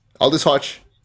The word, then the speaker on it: Aldis Hodge: Aldis Hodge? I hope I'm pronouncing that correct. – Yeah. Aldis Hodge.